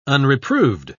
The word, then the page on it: unreproved: British English